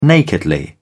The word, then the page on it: nakedly: British English